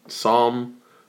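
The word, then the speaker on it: Tsam: It's called Psalm, but the shit's spelled T-S-A-M. I don't know, some Vietnamese shit. But it means like Psalm, like a Bible.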